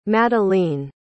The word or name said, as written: Madelene